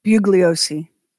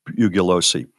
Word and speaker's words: Bugliosi: Bugliosi. – Bugliosi.